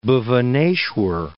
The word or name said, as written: Bhubaneshwar